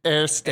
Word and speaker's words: Eerste Divisie: Or is it Eerste Divisie? I don't know. Do you know? – Eredivisie and Eerste. – Eerste. Okay, the Eerste Divisie. – That might be a bit more German pronunciation, but that's how I read it.